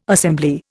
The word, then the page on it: assembly: Indian